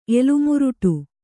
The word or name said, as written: ಎಲುಮುರುಟು